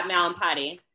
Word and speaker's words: Mallampati: Am I saying that right? – Yeah, Mallampati. Yeah.